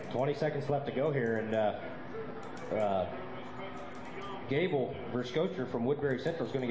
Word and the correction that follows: Verschoer: I believe that's Verschoer. – Verschoer. I've butchered it about six times yep.